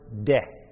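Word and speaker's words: de (δεῖ): It's the Greek word de. It's spelled D-E-I.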